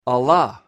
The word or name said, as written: alla